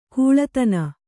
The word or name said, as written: ಖಳಧಾನ